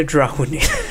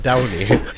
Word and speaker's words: Downey: D-R-O-N-E-I. – Nej, inte Drowney. Downey. – Patrick Downey.